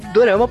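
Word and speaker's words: Dorama (ドラマ): E uma coisa também que é interessante falar é, Dorama é a pronúncia japonesa e quando a gente fala, eu pelo menos me refiro a japonês. Porque em coreano, como que fala, Juba? Ih, cara. é, é durama.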